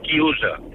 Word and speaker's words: chiusa: Y un momento, y quiero decirle a Fernando que en italiano se dice chiusa.